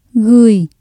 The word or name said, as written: gửi